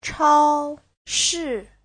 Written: chao1shi4

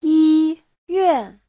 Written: yi1yuan4

